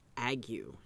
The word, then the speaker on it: ague: Malaria was known during this time as fever and ague. That is spelled A-G-U-E, but it's pronounced ague.